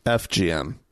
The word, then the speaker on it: FGM: I don't like talking about WGM. Wait, am I saying it wrong? FGM. – FGM.